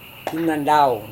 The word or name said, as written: runandaun